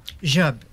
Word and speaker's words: Job: Euh, bon, mais euh, pour commencer, on va faire un, petit parcours sur ta vie, Catherine, parce que euh, on, prononce ton nom Job. Job, oui. Pareil comme euh, Steve Job, mais sauf que c'est s'écrit pas pareil.